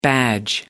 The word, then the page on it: badge: American English